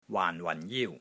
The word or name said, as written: WAAN4 WAN4 JIU1